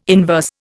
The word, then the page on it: inverse: Indian